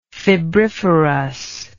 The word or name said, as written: febriferous